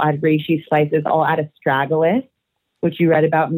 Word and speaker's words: Astragalus: Astragalus.